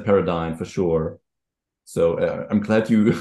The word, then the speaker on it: Paradine: Paradine. I've made note of the fact that it's pronounced Paradine and then said it wrong in the intro.